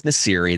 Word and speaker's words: Nasiri: So Justin, last name is Nasiri.